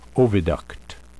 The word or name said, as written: oviduct